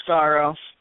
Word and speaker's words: Skaro: Oh, it's Scarrow. – Okay. – How do you spell it? – S-K-A-R-O.